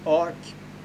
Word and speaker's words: ARQ: Eller ARQ, det är ju en akronym. Men i filmen så uttalar de det ARK.